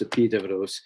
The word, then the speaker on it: Epidaurus: The principal sanctuary of Asclepius was called Epidavros, spelled Epidaurus in English. For people who might be familiar with that, but it's pronounced Epidavros.